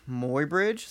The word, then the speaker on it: Muybridge: Okay, so 1872, Edward Muybridge, is that how you pronounce it? – Yeah.